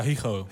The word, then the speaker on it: Higo: Higo, zeg ik dat goed jongens? – Ja, Higo. – Higo. – Spaans, hè? Ja.